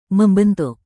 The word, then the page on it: membentuk: Indonesian